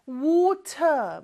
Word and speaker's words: water: 근데 미국이나 북미권에서는 요 T가 모음 소리 사이에 껴있을 때는 리을처럼 발음이 돼요. So 왜 R 발음할 때는 혀가 어디에도 닿으면 안 된다고 했죠? 얘는 입천장에 살짝 닿았다가 떨어지는 소리예요. water, 이렇게 혀를 굴리는 것처럼 그거를 살짝 한번 해주면 돼요.